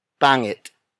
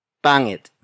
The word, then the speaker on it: pangit: The next word is pangit. Pangit. Pangit. Again, pangit is spelled as P-A-N-G-I-T.